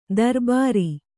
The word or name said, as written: ದರ್ಬಾರಿ